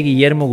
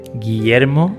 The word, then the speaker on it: Guillermo: José Guillermo Guzzoni Cox. – José. – José Guillermo.